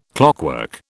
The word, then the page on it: clockwork: British English